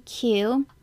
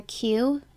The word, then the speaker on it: Qiu: My last name is spelled Q-I-U, but it's pronounced just like the letter Q. A lot of people say it kind of like in weird ways, and I'm just like, no, it's just Q.